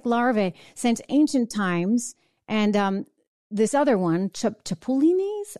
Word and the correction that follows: chapulines: I don't know how to say it, otherwise known as grasshoppers. There you go, grasshoppers. It's a Spanish name. So chapulines, they're eaten in Mexico.